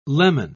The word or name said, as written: leman